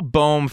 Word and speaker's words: Bohm: But like Hall, like, like a Hall Bohm first base platoon. Is that the right way to say it? Yeah. Good. Nicely done.